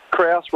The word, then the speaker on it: Krause: Krause rhymes with grouse, which is one of my favourite words.